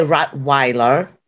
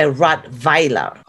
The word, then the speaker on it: Rottweiler: So Germans, we, you know, we pronounce a W as a V. So you say Rottweiler. The Weiler, we say Rottweiler. Okay. It's a V. It's you know, the W in Germany is pronounced as a V, basically. So that's why we say Rottweiler. That's the correct German way.